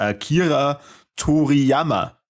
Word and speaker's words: Akira Toriyama: Der Akira Toriyama.